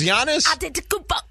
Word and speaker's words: Giannis Antetokounmpo: Three was Giannis. – Antetokounmpo.